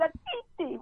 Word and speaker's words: Nkechi: I didn't know N-K-E-C-H-I was supposed to be N-K-C-H-I. I looked at it, it looked like it said N-K-C-H-I. I'm 16, so I said, okay, my name is Natiti, right? And it wasn't until much, much later after I went to college that I saw that I found out I was pronouncing my name incorrectly.